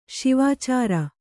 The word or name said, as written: ಸುವಿಚಾರ